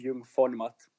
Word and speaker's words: Jung von Matt: Yeah, so it's basically pronounced Jung von Matt.